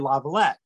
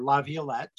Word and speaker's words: Laviolette: One of the most successful NHL coaches is a guy named Peter Laviolette, or Laviolette, depending on how you pronounce it, grew up a couple miles from where I grew up.